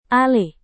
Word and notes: alih: indonesian

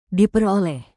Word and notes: diperoleh: indonesian

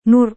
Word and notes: nur: Indonesian

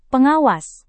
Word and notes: pengawas: indonesian

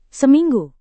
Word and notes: seminggu: Indonesian

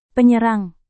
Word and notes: penyerang: Indonesian

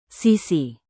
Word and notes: sisi: Indonesian

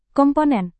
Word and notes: komponen: Indonesian

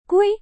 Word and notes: kue: indonesian

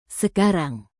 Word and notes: sekarang: indonesian

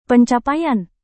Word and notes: pencapaian: Indonesian

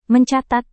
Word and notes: mencatat: Indonesian

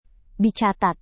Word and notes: dicatat: Indonesian

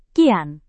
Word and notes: kian: Indonesian

